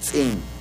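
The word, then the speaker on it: ts'in: En Yucatán se llama tzin.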